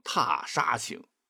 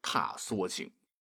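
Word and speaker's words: 踏莎行: 其实啊，沙是草字头加个沙子的沙，这里指缩草，亦称香附子，是一种多年生长的草本植物，可以入药，药毒缩，表示人名地名时才读沙，所以很多朋友会搞错哈，念成踏沙行，应该是踏缩行。那出场最高的一句是雾失楼台，月迷津渡，桃源望断无寻处。不仅是秦观写，你像欧阳修所写的踏缩行出场率也很高。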